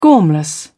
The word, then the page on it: gormless: British English